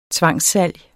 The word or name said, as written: tvangssalg